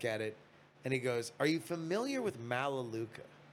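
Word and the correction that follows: Melaleuca: That's I've always heard it pronounced Melaluca,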